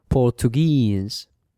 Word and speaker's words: Portuguese: Now the second group is when the word, when the nationality finishes in E-S-E, cuando termina en S, like portuguese, okay? So you say Portuguese. This is important. The stress, el acento, falls on the final syllable. O sea que la sílaba en S siempre es la acentuada. So you say Portuguese.